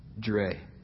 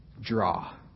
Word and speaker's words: Draa: D-R-A-A. But most people, it's pronounced Dre. Most people say Draw.